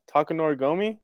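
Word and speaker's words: Takanori Gomi: Takanor Gomi? – Yeah.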